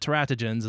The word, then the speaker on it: teratogens: I've also heard it pronounced teratogens and teratogens, however you want to say it, I guess. But teratogens is the way I feel most comfortable saying it, and it sounds right to my English Western speaking ears.